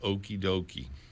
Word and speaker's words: okiedoke: Charlie Parker used a word that is commonly spelled O-K-I-E-D-O-K-E. Spelling it intentionally leaves out a pronunciation guide. The word is commonly pronounced okie-dokie. It is also commonly pronounced. Both pronunciations that I'm using are common, okie-doke.